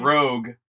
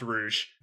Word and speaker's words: Rouge: Rogue. – It's definitely pronounced Rouge. It's hundred percent pronounced Rouge. It's not even spelled the same way as Rogue, you weirdo.